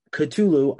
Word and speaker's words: Cthulhu: The final track on the album proper is Cthulhu.